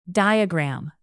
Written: diagram

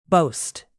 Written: boast

